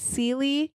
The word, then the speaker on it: seelie: And when learning about the fae, you'll often come across these courts and the phrases seely and unseely. – Okay. – So seely is spelled S-E-E-L-I-E.